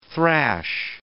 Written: thrash

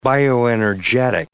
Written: bioenergetic

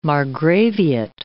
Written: margraviate

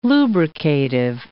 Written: lubricative

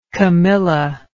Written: camilla

